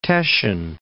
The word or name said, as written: Teschen